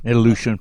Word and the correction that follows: Ilyushin: Ilyushin. Is that how I say that? How do you say that, David? Ilyushin, right? – Ilyushin. – Ilyushin. Okay.